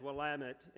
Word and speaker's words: Willamette: And actually, at Willamette, and I appreciate, Judge, you know how to pronounce Willamette instead of Willamette or whatever.